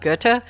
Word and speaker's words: Goethe: I think I was showing you the book from Goethe, which is a German philosopher, I believe. And I was mentioning how you have to kind of say it like you're spitting.